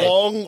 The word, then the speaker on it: Wong: Wong almost rhymes with wrong.